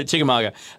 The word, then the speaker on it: Chickamauga: Am I pronouncing that right? Uh, Chickamauga. Yeah. Chickamauga.